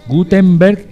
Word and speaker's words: Gutenberg: Por cierto, Gutenberg, ojo que por la pronunciación suena una M, pero es una N. Ojo, es una N. Gutenberg es Gutenberg. Gutenberg. Con N, no con M.